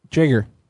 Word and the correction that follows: Jaeger: Are you sure it's not Jaeger? – What is it? – I'm sure I heard him pronounce it whenever I talked to him last week. It's Sam Jaeger.